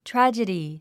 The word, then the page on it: tragedy: trǽdʒədi; トラァジェディ